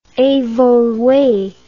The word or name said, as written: evolue